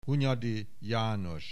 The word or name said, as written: Hunyadi, János